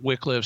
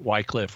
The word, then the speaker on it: Wycliffe: But if it did, it would be because Wycliffe, and it can be pronounced different ways. Some people pronounce it Wycliffe, some people pronounce it Wycliffe. Really doesn't matter.